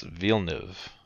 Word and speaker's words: Villeneuve: It's Villeneuve.